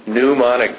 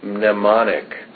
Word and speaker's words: mnemonic: She says mnemonic device, like N-U, and I say, no, Renee, it's M-N, mnemonic.